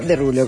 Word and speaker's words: Derulo: Bueno, Derulo, que me estoy enterando ahora que es por cómo se pronuncia su apellido francés, que se escribe con un montón de letras y se pronuncia Derulo con esas seis letritas que él decidió simplificar.